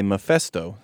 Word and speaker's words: Mephisto: Yeah, so at the end of the book, we are greeted by Mephisto.